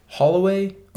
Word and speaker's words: Holloway: Holloway? – Yeah, Holloway.